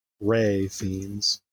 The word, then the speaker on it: Fiennes: And Ralph Fiennes, of course. – Fiennes? – Sorry. – Ray Fiennes.